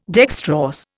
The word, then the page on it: dextrorse: Indian